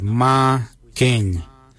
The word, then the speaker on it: marrgeny: The number 6 we say Ma Keng. Ma Keng is spelled M-A-R-R-G-E-N-Y. Ma Keng. For the number 7 we say Ma Kuju. Ma Kuju is spelled M-A-R-R, then it's G-O-O-D-J-A-L.